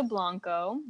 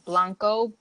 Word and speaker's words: Blanco: Last name is Blanco, Blanco. My Instagram handle is the phonetic spelling of my last name, which is Blanco, but it's spelled weird because it's the phonetic and has a zero. So it's Blahnk0h.